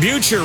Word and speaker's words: Butcher: Coach John Butcher rhymes with future out there at the Lions.